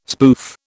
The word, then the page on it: spoof: British English